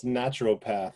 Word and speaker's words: naturopath: It's that extra syllable in there. It's naturopath.